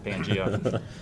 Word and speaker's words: Pangea: Pangea is the correct American English pronunciation of that word is Pangea.